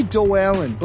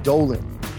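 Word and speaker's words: Doallyn: It could be said two ways. – I think Dualin. – I say Dualin, but some people could say like Dolan.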